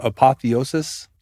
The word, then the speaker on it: apotheosis: Can somebody help me with this pronunciation of this term, apotheosis? – Yeah. – Sounds right. – That's it.